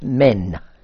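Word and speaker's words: men (μέν): which is pronounced men, M-E-N.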